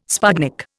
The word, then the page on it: sputnik: Indian